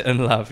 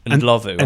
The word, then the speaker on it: Ndlovu: Peter I, Peter Ndlovu, I only knew from like Champ Man when I was really young, and before you know how to pronounce that, you're saying Unlovu.